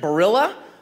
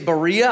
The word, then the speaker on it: Barilla: Barilla, is that how you pronounce it? Like the noodle company? I don't know if they're sold here in America even or not. Maybe it's a European thing. Maybe it's Barilla. I don't know if it's got a Spanish pronunciation. I'm seeing a head nod. Barilla. Anyway, whatever.